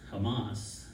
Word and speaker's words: Hamas: You know, there's all the protests going on in capitals and everything about Israel and pro-Hamas and Hamas. I say it wrong. Probably say Kamala wrong too most of the time, but it's Hamas. Yeah, you see where the U.S.